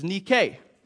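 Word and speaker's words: nike (νίκη): You'd be a lot more familiar with the feminine form, which is nike, or as you pronounce it, Nike.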